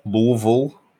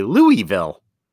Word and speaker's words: Louisville: Louisville? – I think if you're from Kentucky, you say like Louisville.